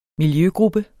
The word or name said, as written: miljøgruppe